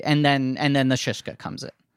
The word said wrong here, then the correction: shiksa: Shiksa. It's Shiksa. Incredible. – I keep saying it wrong.